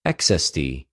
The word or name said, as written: ἐξεστι